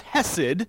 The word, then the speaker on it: chesed: It's spelled C-H-E-S-E-D. And since I have no accent whatsoever other than, you know, American, I can't pronounce it in the Hebrew way, but it's hesed, okay?